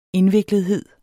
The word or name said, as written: indviklethed